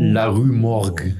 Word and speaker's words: La Rue Morgue: Wie es ausgesprochen wird, Le Rue Morgue. – La Rue Morgue.